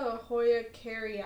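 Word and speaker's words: Hoya kerrii: Yeah, that one's the Hoya carrii. That's how you pronounce it.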